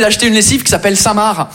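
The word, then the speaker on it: Saint Marc: On est d'accord, les Français, que Samar, ça s'écrit M-A-R-C, comme le prénom Marc. Mais vous, vous mettez un sein devant le prénom Marc, le C, il est parti en grève.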